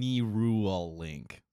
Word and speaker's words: Neuralink: Um, I can't say it right now because my it's actually pronounced right. Neuralink.